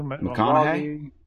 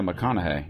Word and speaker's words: McConaughey: How do you say the guy's name? Matthew Mahoghi? – McConaughey? – That's the way you say it? – Yeah, McConaughey. Yeah, Matthew McConaughey. Or McConaughey. Yeah.